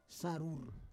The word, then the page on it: sarur: Roon